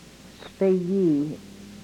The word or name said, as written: spegii